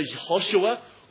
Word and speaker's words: Yehoshua: Jesus, as I'm sure we know, comes from the Hebrew, and I'm pronouncing two alternatives here, either Yehoshua or Yeshua, which we know in the Hebrew is Joshua when it comes to the translation in English.